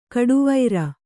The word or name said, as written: ಘಟ್ಟವರೆ